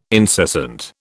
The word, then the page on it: incessant: British English